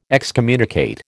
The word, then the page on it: excommunicate: American English